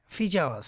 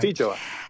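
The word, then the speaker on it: Feijoa: You've got, and again, excuse my pronunciation, Fifi La Fioa? Fijoa, sorry. – Fijoa.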